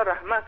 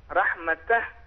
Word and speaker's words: رحمتہ: اور اس سے ہٹ کے بھی انہوں نے جو وقف کیا تو لفظ کا صحیح تلفظ ادا نہیں کیا، رحمتہ کو انہوں نے رحمہ کہا، جبکہ اگر ہم یہاں پر وقف کریں گے تو رحمتہ پڑھنا چاہیے.